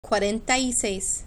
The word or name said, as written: cuarenta y seis